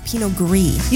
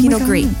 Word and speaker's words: Pinot Gris: For wine plebs like yourself, you would pronounce the S. But, and we always have a little fun fact in our episodes. So this wine fun fact, is Pinot Gris. You do not pronounce the S. It's French.